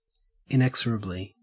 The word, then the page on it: inexorably: Australian English